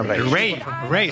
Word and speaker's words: Raye: Ray, se escribe Raye.